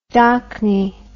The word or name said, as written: docknie